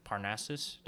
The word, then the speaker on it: Parnassus: Yeah, with how he did Joker, and then if you've seen the Imaginarium of Dr. Parnassus, did I say that correctly? – Yep.